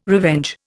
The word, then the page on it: revenge: Indian